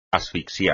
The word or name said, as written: asfixiar